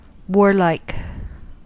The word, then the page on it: warlike: American English